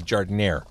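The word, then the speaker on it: giardiniera: So it looks like there might be jardinera on here, or actually because we're on the south side, I would say jardinere, right? Mm-hmm. You don't pronounce it... with the once you're south of Congress Parkway.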